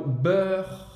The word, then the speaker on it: beurre: Talvez uma dica de pronúncia para falar o beurre. Beurre, como seria? – Beurre. Uh, uh, uh. Você vai fazer a boca do O aberto, tá? E fala é.